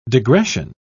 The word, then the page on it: degression: British English